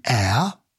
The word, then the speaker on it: heir: And because the H is silent in an heir, as in heir to the throne, an hour of time, an honour, to receive an honour, where the H is pronounced, we use an A.